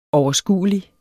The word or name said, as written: overskuelig